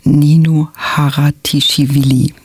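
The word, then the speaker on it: Nino Haratischwili: Und heute spreche ich über das achte Leben für Brilka von Nino Haratischvili. So spricht man sie nämlich.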